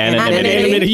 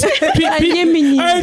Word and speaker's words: anonymity: Bravo actually said in the chat, he said, behind the mass of I'm going to say this wrong. – Anonymity. – Yes. – Anonymity. – Okay, there we go.